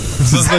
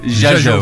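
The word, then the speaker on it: Zsa Zsa: Zaza yazılır, Jaja okunur.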